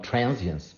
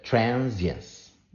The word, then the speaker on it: transience: Yes, Carmencita, you were asking me about the pronunciation of transience, and that's it. Transience. That's it. It's like science, but there is a trans and it's a short I, so it's transience.